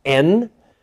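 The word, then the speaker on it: en (ἦν): Now, the word therefore was is in, or could be pronounced en in Greek.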